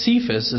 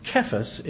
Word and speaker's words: Kephas: You shall be called,'" and we usually pronounce this name Cephas, which is translated a stone. Now the name that we mispronounce as Cephas is actually pronounced Kephas in Greek.